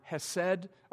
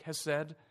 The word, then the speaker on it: hesed: Steadfast love, it's the Hebrew word hesed, or pronounced something like hesed.